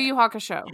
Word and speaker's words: Yu Yu Hakusho: Yu Yu Haka? How do you say that? – Yu Yu Hakusho.